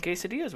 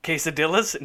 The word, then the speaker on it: quesadillas: so we made some quesadillas with it tonight. It was pretty good. – I think in Minnesota you actually pronounce the L's. Is that correct? – Quesadillas? No, but yeah.